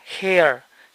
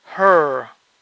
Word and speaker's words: her: Ojo que no se pronuncia hair, sino her.